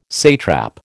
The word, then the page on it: satrap: American English